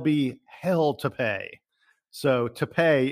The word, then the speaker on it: Tapé: Yeah, and first of all, the most important thing his his last name is spelled T A P E, but you are correct; it is not pronounced tape like Scotch tape.